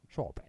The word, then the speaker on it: shoppe: Like how they spell shop, S-H-O-P-P-E, with an accent. – Don't you just love it? – Shop. Sure, yeah, exotic. – Well, they say that apostrophe or that E at the end wasn't actually pronounced, and it was always just shop.